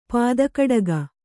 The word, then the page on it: ಪದಕಟಕ: Kannada